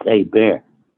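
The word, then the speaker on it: Hébert: And she said, now, we don't pronounce the H. It's not Hebrew. It's A Bear.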